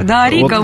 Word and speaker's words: Дарика: Дарика, вот, ударение на «и».